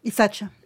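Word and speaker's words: Isaccea: Isacha. – Isacha. – Ah, je l'ai mal prononcé. – Oui, Isacha.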